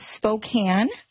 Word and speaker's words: Spokane: It is Spokane, okay.